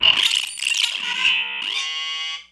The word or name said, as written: say it again